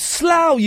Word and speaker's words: Slough: It's pronounced Slough, you idiot.